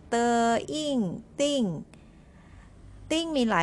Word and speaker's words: 订: ติ้ง